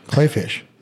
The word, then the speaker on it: Kleefisch: Kleefisch. Kleefisch. It's pronounced Klee. Or, I mean, it looks spelled Klee. – It's German. Klee is clover. Clay.